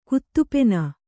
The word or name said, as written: ಕುತ್ತುಪೆನ